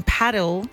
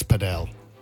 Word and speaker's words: padel: Well, if you like squash a little bit and you also like tennis, then paddle. – Yeah, but sorry, can I just stop you? It's padel. – What? – Many people pronounce it padel.